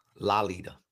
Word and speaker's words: Lolita: Lolita. – I'm sorry. – I only know that because the proper way to pronounce it is like lollipop.